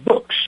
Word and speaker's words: Boox: From Fort Myers, Florida, please welcome with me Patty Broussard Jefferson of PJ Books, and that's spelled B-O-O-X.